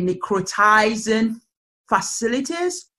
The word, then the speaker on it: necrotizing fasciitis: I think it's known as Nick, and please correct me if I don't say it correctly, necrotizing fasciitis.